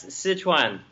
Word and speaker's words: Sichuan: That's Sichuan.